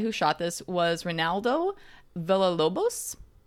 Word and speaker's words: Villalobos: Okay, you said that exactly the way that Butch's character in Pulp Fiction says the name of the taxi driver, Villalobos. – Am I saying it wrong? – I don't think so.